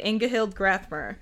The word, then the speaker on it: Ingahild Grathmer: Ingahild Grathmer is what I'm going to say. – That's good. Yeah, I think that's how you say it. Ingahild Grathmer.